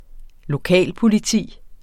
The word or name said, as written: lokalpoliti